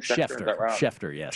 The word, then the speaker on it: Schefter: Have your notifications on from the insiders in the game, like Adam Schefter, is that his name? – Schefter. – Yes. Sorry.